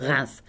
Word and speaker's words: Reims: Spelled Reims, of course, pronounced Reims.